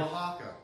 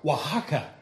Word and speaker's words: Oaxaca: But Grenada, Malawi, Togo, and how do you pronounce that? Oaxaca? Is that Oaxaca? – Oaxaca.